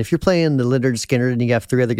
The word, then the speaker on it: Lynyrd Skynyrd: Educate you that the correct pronunciation was Lynyrd Skynyrd.